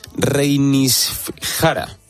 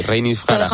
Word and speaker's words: Reynisfjara: Se trata de, a ver si soy capaz de pronunciarlo bien, Reynisfjara. – Lo has hecho genial. Jara. – A ver, ¿cómo lo dirías tú? – Yo creo que no puedo superar tu pronunciación.